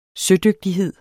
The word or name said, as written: sødygtighed